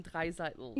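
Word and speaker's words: Draisaitl: I am closer to the Leon Dreisaitl generation. Sorry, I said it wrong. You can correct me. I can see the pain in your face. – Ah, yes. Puff is from the Leon Dreiseitel generation.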